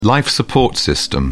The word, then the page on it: life-support system: British English